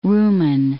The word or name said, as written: rumen